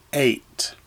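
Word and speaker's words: eight (את): And after the word God in Hebrew comes the letters Aleph and Tav, and it's pronounced eight.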